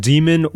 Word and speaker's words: daemon: I got another message from the mailer demon saying I put in the wrong email. What is Julia's email? Demon. D-A-E-M-O-N. Demon. Pronounced demon or daemon, both are apparently correct.